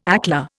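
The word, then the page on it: éclair: Indian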